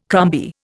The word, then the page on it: crumby: Indian